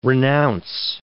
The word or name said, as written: renounce